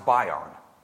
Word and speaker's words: bion: But that word is pronounced bion, B-I-O-N.